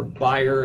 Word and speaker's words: Bayer: Bayer, or Bayer, if you prefer the German pronunciation, acquired Monsanto.